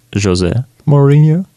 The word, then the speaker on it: José Mourinho: You say his name a little differently than we say it in Russia. In Russia, we say José Mourinho.